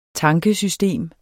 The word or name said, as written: tankesystem